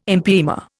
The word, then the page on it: empyema: Indian